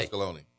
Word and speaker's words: Pasqualoni: You said it right. Yeah. Pasqualoni.